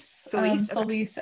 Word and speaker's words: Solis: Solis, as in Gabrielle Solis. Okay.